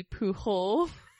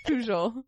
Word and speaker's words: Pujol: So if you were to pronounce his name, like if he were, I don't know, Spanish, it would be Pujol. But it's Pujol, because he's French.